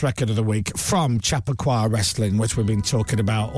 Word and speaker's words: Chappaqua Wrestling: That's Chappaqua Wrestling. Chappaqua is the best way to pronounce it properly.